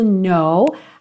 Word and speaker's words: know: Repara que o K da palavra know é mudo.